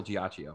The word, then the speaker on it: Giacchino: Yeah, because he's in a video and he goes, Michael Giacchino. Yeah, hang on. I'll share it with you guys. So you guys... – Oh, Giacchino. – Giacchino.